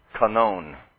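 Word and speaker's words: kanon (κανών): And it comes from this Greek word, which is pronounced kanon. You hear it sounds the same. Just the accent really is different.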